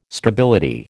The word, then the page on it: stability: American English